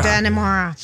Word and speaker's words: Dannemora: Dannemora.